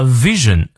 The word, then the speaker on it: vision: A vision 啊，注意上排牙齿摩擦下排嘴唇。 v v。